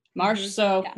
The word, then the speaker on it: Marchessault: I think that he's been Marchessault.